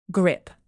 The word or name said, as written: grip